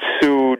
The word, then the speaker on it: Suge: I don't mean to listen, Mike, I don't mean to be the guy who's correcting you on your own show, but it's Suge Knight. – Okay, I've never heard his name pronounced as Suge. Are you sure his name is? It was always, I thought Suge. It was short for sugar. – Well, you know, Suge has got a bit of a reputation. A lot of it comes from people who just get the name wrong. – Well, it's S-U-G-E, Suge.